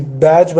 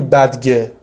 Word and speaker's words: badge: از اون ورم برای همچین واژه‌ای باید تلفظش هم درست بگی، مثلا نگی بدگه، نمیدونم بادگه، یه همچین تلفظی نکن.